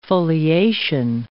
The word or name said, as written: foliation